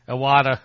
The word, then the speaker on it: Iwata: And then I would, you know, I will give, even though we reported his passing, I'm gonna give a shout out to, Mr. Wada? I won't, yeah. I probably said his name wrong. What, help me out here. – Iwata. – There you go.